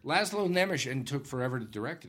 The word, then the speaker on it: Nemes: Laszlo Nemes, I think? – Nemesh. – Nemesh. – Yep, that's right. Laszlo Nemesh.